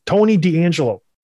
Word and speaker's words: Tony DeAngelo: Tony DeAngelo. – Nailed it. Said it right.